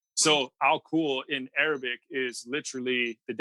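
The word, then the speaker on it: Al-kul (الكحل): The word alcohol is an Arabic word. Al-kul is how you would pronounce it.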